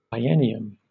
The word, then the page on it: biennium: British English